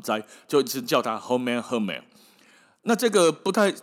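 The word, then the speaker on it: Hummel: 哦，用一句话笑他叫做 “Hermel Hermel”，那个发音哦，有一点像是台语的“ 赫北赫北” 啦。哦，阿是讲红妹，就是好了没的赫北，跟被骂被人家骂的红妹。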